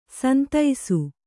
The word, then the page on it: ಸಂತಸ: Kannada